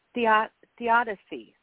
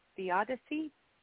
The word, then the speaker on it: theodicy: So your book, House of Souls, is a narrative theodicy, right? Theodicy? I said it right.